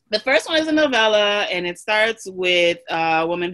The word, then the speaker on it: novella: Wait, am I saying that right? Is it novella? – Yeah. – It's novella.